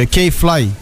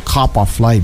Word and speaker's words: K-Fly: Nu, nu, e K-Fly. Știi că data trecută am pronunțat capa Fly, deci am dat-o pe românește de am julit-o.